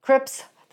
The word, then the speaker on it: Crips: Creeds, or am I saying that right? Crips, the Crips.